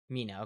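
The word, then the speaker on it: Mena: Now I want to make sure I pronounce her name. Is it Mina Massoud? – Mina.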